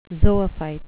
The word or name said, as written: zoophyte